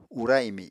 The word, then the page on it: ura imi: Apatani